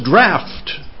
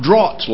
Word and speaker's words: draught: I emphasize that because this is the most mispronounced word in the Bible. It's spelled just like laugh. It's pronounced just like laugh. And so it's draft. It's not draught like you hear a lot. It's draft. And